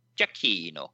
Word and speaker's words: Giacchino: Jeg ved ikke, hvordan det skal udtales. – Giacchino, så siger vi det.